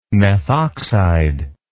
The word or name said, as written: methoxide